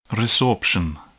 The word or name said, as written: resorption